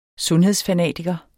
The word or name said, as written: sundhedsfanatiker